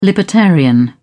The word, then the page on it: libertarian: British English